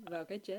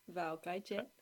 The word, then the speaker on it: Vào cái chết: Maybe you can say it. Vao Ca Hai Chet. VaoCaiChet. – Vào cái chết. – I'm after. How do you say it? – Vào cái chết.